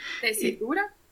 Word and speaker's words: tesitura: Este, y pues me di cuenta que hay varios, varios términos eh, muy técnicos, em, como es la tesurita, no sé si lo estoy pronunciando bien. – ¿Tesitura? – Tesitura, ok, perdón, perdón.